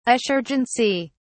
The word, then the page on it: assurgency: British English